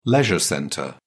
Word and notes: leisure centre: British English

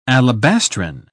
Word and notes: alabastrine: British English

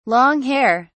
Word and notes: long-hair: British English

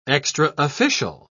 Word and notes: extraofficial: British English

